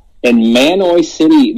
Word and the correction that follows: Mahanoy: Manoy. How do you say it? Manoy City? – Mahanoy. – Mahanoy City.